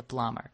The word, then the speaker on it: plumber: Вот эта буква «b» не читается, и по-английски «сантехник» — это «plumber».